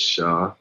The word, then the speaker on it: Shah: My name is Shah. That's spelled S-H-A-H. Everybody swears it's Sean when I introduce myself.